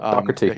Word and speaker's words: Doherty: No, it's Dore. – No, Doherty. You got to pronounce it just like the announcers do. It's Doherty.